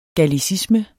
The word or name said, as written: gallicisme